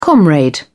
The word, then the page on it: comrade: British English